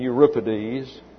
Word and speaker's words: Euripides: A-E-S-C-H. I left the C out. A-E-S-C-H-Y-L-U-S. That's Aeschylus. And then Sophocles. S-O-P-H-O-C-L-E-S. And then Euripides. E-U-R-I-P-I-D-E-S.